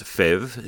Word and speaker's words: PHEV: The PHEV, pronounced FEV, is probably the best mix as you can drive every day on all-electric power if you don't go too far.